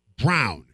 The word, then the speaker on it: Braun: I think Christian Braun's going to be a Braun. Brown. – Right. – I write it B-R-A-U-N every time, and I always pronounce it Brown.